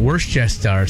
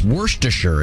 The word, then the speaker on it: Worcestershire: Worcestershire sauce is the big one. Worcestershire. – It's Worcestershire, isn't it? – Is that how you're supposed to do it? Worcestershire? – It makes me think of that Holmes cooking guy who always says, "Who's your sister sauce?" – Yeah, that's so much easier.